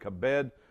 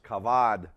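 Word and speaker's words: kavad (כָּבֵד): Now, by the way, strong force there, that word there is this word, Hebrew word, kabed, or it's pronounced kavad.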